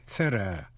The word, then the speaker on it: thorough: Quý vị nhớ là chữ thorough, T-H-O-R-O-U-G-H, có hai cách phát âm. Phát âm theo người Anh, thorough. Phát âm theo người Mỹ, thorough.